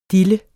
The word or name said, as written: diller